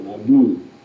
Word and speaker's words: Nabu: Another, Sin, S-I-N, which we used last time and talked about in some name, and Nabu, the accent on the second syllable, Nabu.